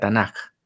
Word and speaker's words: Tanakh: La primera letra de cada una de estas tres partes, T de Tora, N de Nevi'im y K de Ketuvim, forman un acrónimo, TNK, que se pronuncia Tanakh.